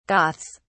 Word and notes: goths: British English

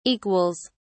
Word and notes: equals: British English

